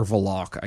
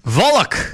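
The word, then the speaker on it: Volokh: Law professor Eugene Volk, or Volok, I guess would be the proper pronunciation. – Volok! – Volok.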